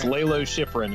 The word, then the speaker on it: Lalo Schifrin: Yeah, I think it's Lalo Schifrin is how you pronounce that.